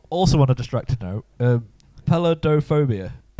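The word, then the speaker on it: peladophobia: Pelodophobia.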